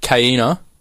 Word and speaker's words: Kaina: Could be a curse by Kaina. I want to say that's how you pronounce her name. – Yep.